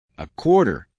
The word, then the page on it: a quarter: American English